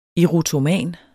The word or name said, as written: erotoman